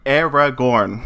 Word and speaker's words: Aragorn: Aragorn.